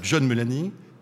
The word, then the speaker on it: John Mulaney: Il y a John Mulaney. Je l'ai bien prononcé. – Bien joué.